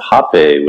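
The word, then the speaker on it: rapé: Rape, it's R-A-P-E with a little dash on it. I think most people pronounce it hape with an H, because that's sort of closer to the indigenous pronunciation.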